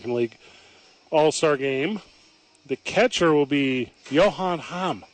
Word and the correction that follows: Heim: It's not Johan Hamm. – No. Heim.